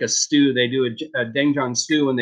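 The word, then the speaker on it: Doenjang (된장): That one's the doenjang. So that's the fermented soybean paste. It's a little bit different. – Tell me how to pronounce this again. – Doenjang. – Doenjang.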